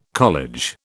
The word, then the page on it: college: British English